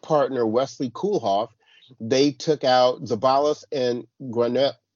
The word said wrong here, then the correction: Granollers: I always say his name wrong. – Yeah, Granollers. – Granollers. Thank you very much.